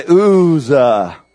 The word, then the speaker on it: Uzzah: And Uzzah, say Uzzah, or you can say it like this, Uzzah, and his brother there, and the sons of Abinadab, and or drove the new cart.